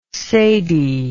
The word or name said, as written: sadie